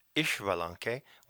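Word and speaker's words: Xbalanque: which is an X, and can also be pronounced Ishbalanque when it has an I in front of the X.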